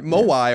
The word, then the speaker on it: Moai: That's spelled like Hawaii, Maui. Moai or M-O-A-I are the weird statues on Easter Island.